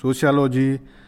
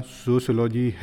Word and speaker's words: सोशियोलॉजी: हमें सोशियोलॉजी शब्द के उच्चारण में विशेष सावधानी रखनी चाहिए। छात्र छात्राएं इसे त्रुटिपूर्ण ढंग से सोशियोलॉजी आदि उच्चारित करते हैं। सही उच्चारण सोशियोलॉजी है।